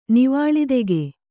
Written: ನೀವಾಳಿತೆಗೆ

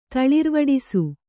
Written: ತಳಿರ್ವಡಿಸು

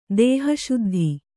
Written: ದೇಹಶುದ್ಧಿ

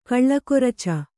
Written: ಕಲಗರ್ಚು

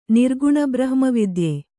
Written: ನಿರ್ಗುಣಬ್ರಹ್ಮವಿದ್ಯೆ

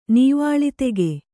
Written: ನೀವಾಳಿತೆಗೆ

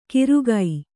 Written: ಕಿರುಗೈ

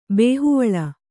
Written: ಬೇಹುವಳ

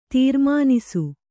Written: ತೀರ್ಮಾನಿಸು

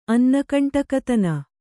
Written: ಅನ್ನಕಂಟಕತನ

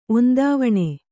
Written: ಒಂದಾವಣೆ

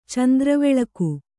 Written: ಚಂದ್ರವೆಳಕು

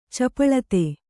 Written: ಚಾಪಲತೆ

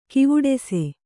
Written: ಕವಡಿಸು

